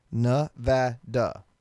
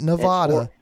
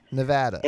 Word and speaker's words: Nevada: Nevada. Say it right. I will get into a whole freaking fight with you about this. Nah. Say it with me. Ready? Nah. – Nah. – Vada. Nevada. Not Nevada. Nevada.